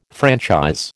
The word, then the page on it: franchise: American English